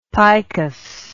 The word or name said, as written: picus